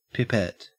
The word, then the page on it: pipette: Australian English